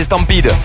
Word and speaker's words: Stampede: Stomperode. – Stomperode. Oui, je ne connais pas. Je connais Stampede, si tu veux. – Oui, Stampede. – Ah bah non, c'est pas Stomperode, c'est Stampede.